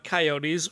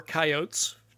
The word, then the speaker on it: coyotes: So we are learning about coyotes or coyotes today. Both pronunciations are correct.